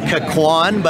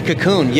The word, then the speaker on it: Cocoon: And you might try to pronounce it as like Kakwan, but it's actually Cocoon.